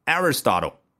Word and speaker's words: Aristotle: 啊，你听出来这个美式发音当中亚里士多德这个英文名叫 Aristotle, 那英国人呢啊，这个 t 就特别是墨菲的 t 发得更清晰一点，然后那个 r 的部分它会发得更圆一点，就是没有美国人的嘴型那么夸张，所以是 Aristotle。